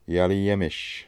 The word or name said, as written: Yagliyemmish